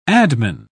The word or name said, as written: adman